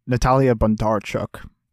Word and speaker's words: Natalia Bondarchuk: I think that the real standout is Natalia Bondarchuk. Or let me pull up my notes because I wrote a phonetic pronunciation for it. I wanted to get it right. Natalia Bondarchuk.